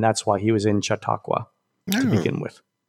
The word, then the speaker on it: Chautauqua: By the way, Nicole, who's from, what's the institute that Michael Chautauqua. Eisner went to? – Chautauqua.